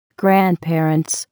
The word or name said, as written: grandparents